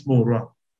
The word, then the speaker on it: Murua: As it appears there, my name is Murua, because I know my two barrel name is too long for others to pronounce it. But you can simply call me Murua.